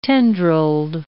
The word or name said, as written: tendrilled